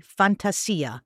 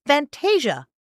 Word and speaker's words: Fantasia: but it's pronounced Fantasia. What's up with that? Well, what's up with that is pretty much Disney changed the pronunciation of the word to make it what they felt would be more palatable to American audiences.